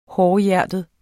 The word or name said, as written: hårdhjertet